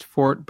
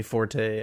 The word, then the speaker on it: forte: I know that it can be pronounced fort, but I think I usually only say it that way when I am being pretentious. – I feel like it's an American colloquialism to say forte, and that if you say fort, people will be like, I think I know what you're trying to say, but what are we doing here? – Yeah, exactly.